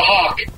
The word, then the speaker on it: Hauck: Um, I'm a hawk. H A U C K. Just like the bird.